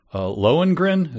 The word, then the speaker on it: Lohengrin: Lohengrin, is that how you pronounce that? – Yeah.